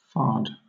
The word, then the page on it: fard: British English